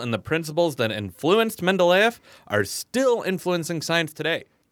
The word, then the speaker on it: Mendeleev: Mendeleev. Mendeleev. – Okay. – Mendeleev. Mendeleev.